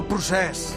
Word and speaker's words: procés: El procés.